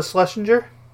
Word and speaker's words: Schlesinger: So the only thing, Liza Schlesinger has a Schlesinger, that's how you pronounce that, has a sketch show coming on Netflix in like the second week of our next 2 weeks.